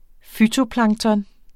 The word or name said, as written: fytoplankton